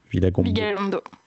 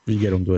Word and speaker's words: Vigalondo: j'arrive pas à prononcer son nom, Vigalondo. – Vigalondo, à chaque fois je me trompe.